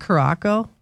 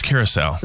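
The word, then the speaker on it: Curaçao: Isn't that Curacao? – No, it's Curacao.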